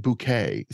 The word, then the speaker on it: Bucket: Her name is Hyacinth, and the last name of this couple is spelled B-U-C-K-E-T, Bucket. She pronounces it bouquet.